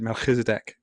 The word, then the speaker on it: Melchizedek: He said Melchizedek, which is probably more like the Hebrew pronunciation. I'd be doing it Welsh style.